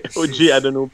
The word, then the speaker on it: OG Anunoby: Du côté des Raptors, écoute, il y a un joueur que je m'attends qui emmène beaucoup, c'est OG Anunoby. J'ai-tu de la misère à le prononcer? – C'est correct. OG Anunoby.